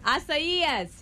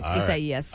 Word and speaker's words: Isaías: The pronunciation. Isaías. Here we go. – Isaías. – That's how you say it. Isaías.